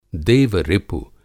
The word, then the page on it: देव-रिपु: देवरिपु; Hindi